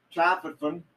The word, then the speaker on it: tryptophan: But anyway, potatoes are rich in tryptophan.